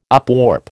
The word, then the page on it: upwarp: American English